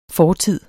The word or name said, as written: fortid